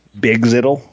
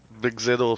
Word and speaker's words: BGZTL: How are we going to officially pronounce this? Bigzittle. – Bigzittle is fine.